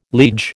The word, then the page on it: liege: American English